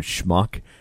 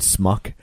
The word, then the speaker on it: schmuck: Like, there's a funny scene in the movie where Dustin Hoffman is trying to teach Warren Beatty how to say the word schmuck, because he keeps saying smuck.